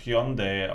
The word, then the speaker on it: Hyundai: du, dass Hyundai eigentlich Hyundai ausgesprochen wird? – Ja, ja, ja, genau. Es, es klingt so wie Honda.